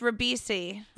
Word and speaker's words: Ribisi: Because his name is pronounced Ribisi.